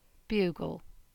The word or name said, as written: Bugle